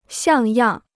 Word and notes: 像样: xiàng yàng

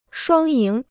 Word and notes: 双赢: shuāng yíng

